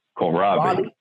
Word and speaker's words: kohlrabi: Kohlrabi. – Kohlrabi.